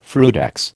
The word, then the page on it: frutex: American English